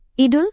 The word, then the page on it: idul: Indonesian